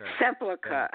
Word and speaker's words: Sepulchre: Sepulchre.